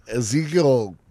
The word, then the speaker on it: Ezekiel: Ezekiel pretty much you know, rhymes with evil.